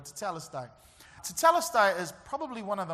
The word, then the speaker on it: tetelestai: tetelestai. Everybody say tetelestai. You can say it like you're not stuttering.